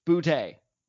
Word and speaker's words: Boutte: Kayshon Boutte. It's Boutte.